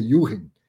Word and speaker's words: Junchen: Escreve Junchen, né? Mas eh, pronuncia Juren me deu aula de tributação, depois foi meu aluno no curso de fundos imobiliários, um, um amigo de longa data aí.